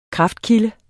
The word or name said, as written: kraftkilde